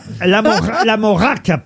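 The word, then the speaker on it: Lamorak: L'Amora. Pas la C'est de la moutarde. – Non. L'Amorac, pardon. Je dois prononcer le K, je crois, qui se trouve à la fin de l'Amora.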